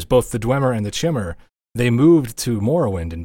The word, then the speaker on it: Chimer: But all you need to know is that the Chimmer is how I'm going to pronounce it.